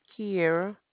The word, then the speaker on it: Kiara: Kiera.